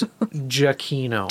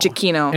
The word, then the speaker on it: Giacchino: I'm going to butcher his oh. last name because it's so Italian. – It's Giacchino. – Giacchino.